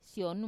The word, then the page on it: sionu: wamesa